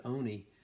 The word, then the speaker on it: Olney: It's spelled like Olney, but I think the English pronounce it Oni, and um, it was a mill town, so a bunch of mill mm-hmm.